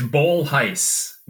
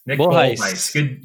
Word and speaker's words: Bolhuis: It's Bolhuis. Nick Bolhuis. Good Dutch name.